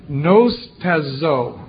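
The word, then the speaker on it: nostazo (νυστάζω): Now, the Greek word in Strong's for slumber, 3573, it's pronounced nostazo. Nostazo, sort of like snooze.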